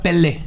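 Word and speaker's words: Pelle: Dead se escribe P-E-L-K, una cosa así. Se, se pronuncia Pele.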